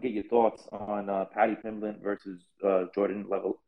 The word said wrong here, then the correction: Leavitt: I can never pronounce his last name. Leavitt. I think his name is Leavitt. – Leavitt. There you go.